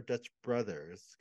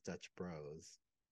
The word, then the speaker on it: Dutch Bros: And I said, is it Dutch Bros or Dutch Brothers? Because they shortened the brothers to BROS. – Mm-hmm. – And she said the proper pronunciation is Dutch Bros.